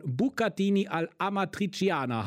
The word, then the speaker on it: Bucatini all'Amatriciana: Also, wir reden jetzt hier von Bucatini all'Amatriciana. Hallo Stefan, habe ich das richtig ausgesprochen? – Hallo, das hast du perfekt ausgesprochen.